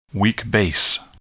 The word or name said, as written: weak base